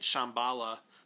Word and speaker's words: Shyamalan: Well, and it suffered from the M. Night Shyamalan, which I understand isn't how you pronounce his name, but yes, it is.